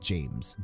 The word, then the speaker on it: Jaimes: Wide Awake featuring, I'm hoping it's pronounced James, J-A-I-M-E-S. – Yeah, that's how I would say it.